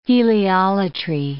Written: heliolatry

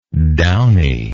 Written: downiness

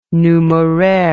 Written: numeraire

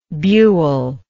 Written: buell

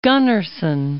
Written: Gunnarsson

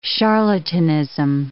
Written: charlatanism

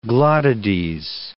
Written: glottides

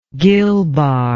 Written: gill bar

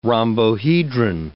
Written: rhombohedron

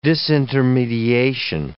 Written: disintermediation

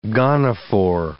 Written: gonophore

